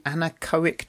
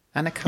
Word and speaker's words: anechoic: Tell us about the anechoic chamber. Have I pronounced that right? Anechoic.